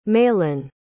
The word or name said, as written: maylin